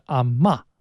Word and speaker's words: 阿嬷: 在闽南、潮汕或者台湾啊，如果是喊奶奶或者外婆的话，它的口语发音就是阿骂。